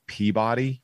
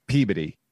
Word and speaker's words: Peabody: Massachusetts has a town that's spelled Peabody. And if you call it Peabody, you will be destroyed. It's Peabody.